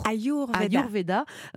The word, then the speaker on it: Ayurveda: Euh, qu'est-ce que ça veut dire d'ailleurs Ayurveda Je ne sais pas si je le dis bien, on dit Ayur ou Ayur Ayurveda.